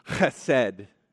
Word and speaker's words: chesed: The Hebrew word is chesed. I don't know if I can say that right. Callan could help me. It's chesed.